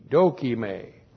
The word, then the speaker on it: dokime: D-O-K-I-M, long E, dokime.